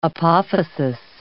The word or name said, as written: apophysis